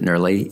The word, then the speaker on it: NRLI: I'm a faculty member here at the University of Florida, and NERLI, N-R-L-I, we pronounce it NERLI, is an extension program in the School of Forest, Fisheries, and Geomatic Sciences.